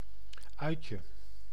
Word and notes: uitje: [ˈœytjə]

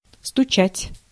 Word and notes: стучать: [stʊˈt͡ɕætʲ]

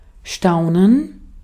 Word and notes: staunen: /ˈʃtaʊ̯nən/

